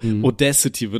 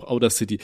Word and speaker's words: Audacity: Ich glaube, Audacity wird das tatsächlich ausgesprochen, aber ich finde, das klingt richtig kacke, Alter, ich nenne das auch Outer City.